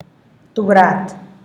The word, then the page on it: tubrat: Teotitlán del Valle Zapotec